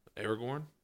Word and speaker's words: Aragorn: Aragorn's quest. Is it Aragorn? Am I saying that right? – Aragorn? – Aragorn.